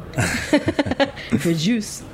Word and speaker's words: Fréjus: It's a small town on the Riviera called Fréjus in Provence. – That's why I skipped saying it, because I knew I was going to say it wrong. Fréjus.